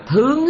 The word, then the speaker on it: thướng: Cũng giống như chữ thượng á, trong tiếng Hán mình đã viết chữ thượng á mà họ đọc hai cách, thượng mà trạng từ á, có nghĩa là ở ở trên, mà thướng á nó có nghĩa là đi lên trên, cũng cái chữ đó nhưng mà đọc là thướng, khi nó là động từ á thì phải đọc là thướng.